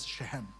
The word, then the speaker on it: Shechem: Okay, Shechem, but when you really pronounce it, it's Shechem.